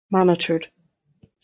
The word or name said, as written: monitored